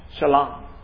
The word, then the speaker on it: Shalom: Now in the Hebrew, Salem would be pronounced, the S would be pronounced with the SH sound. Shalom. Shalom. How many of you know what Shalom is?